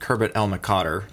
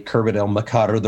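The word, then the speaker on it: Khirbet el-Maqatir: So Americans say Kerbet el Makadr. The rest of the world says Makadr. So I go back and forth.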